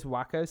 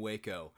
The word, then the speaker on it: Waco: By the way, I know it's not necessarily the topic of the video, but have you seen there's all this Wacko stuff? – What stuff? – Waco.